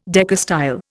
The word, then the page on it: decastyle: Indian